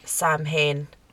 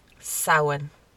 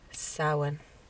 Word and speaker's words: Samhain: If you're American, please, for the love of God, I'm not even religious, but please, for the love of God, stop calling it Samhain. It's Samhain. – I do get it though, because of the way it's spelled. – Oh yeah, it makes sense, but it's Samhain.